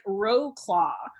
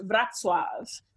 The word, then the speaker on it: Wrocław: The city on the map looks like Wrocław, but it's actually pronounced Wrocław.